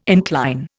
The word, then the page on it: incline: Indian